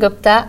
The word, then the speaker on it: Gupta: Today I have with me Harini Gupta.